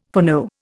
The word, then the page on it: foreknow: Indian